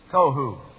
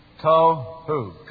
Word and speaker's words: Tohu: That word's kind of interesting. Tohu. And there's the vowel sound there, the vowel sound there. Tohu. Two consonants, two vowels. Tohu.